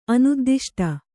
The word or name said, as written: ಅನುದ್ದಿಷ್ಟ